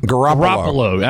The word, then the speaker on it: Garoppolo: I can never pronounce the starting – his name's Jimmy, but I can never – Garoppolo or – Garoppolo. – How do I pronounce his last name? – Garoppolo. – Absolutely, right. Yeah.